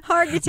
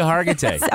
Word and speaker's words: Hargitay: My favorite detective um, is Mariska Haggerty because I like her. hey, how do you say her name? – Hargitay. – Mariska Hargitay.